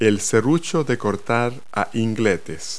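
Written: miter saw

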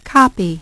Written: Copy

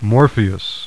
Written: Morpheus

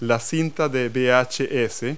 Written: VHS tape

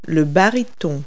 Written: baritone

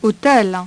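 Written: hôtel

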